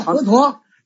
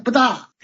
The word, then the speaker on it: 佛陀: 那你就像佛陀，这一个就不翻。佛陀这就是我们就是实际上他应该念不大，就这么念的，现在念成佛陀实际上不大。